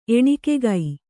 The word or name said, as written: ಎಣಿಕೆಗೈ